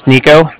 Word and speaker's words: Niko: Again, my name's Nico. That's N-I-K-O. – N-I-K-O? – Okay.